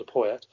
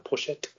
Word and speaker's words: Poyet: under Poyet, Pochet, as he should be pronounced.